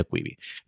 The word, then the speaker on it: Quibi: Quibi son las siglas, o mejor dicho, la contracción de Quick Bites, con lo cual decían ser Quibi. Pero bueno, el caso es que se pronuncia Quibi.